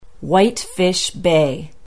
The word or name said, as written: whitefish bay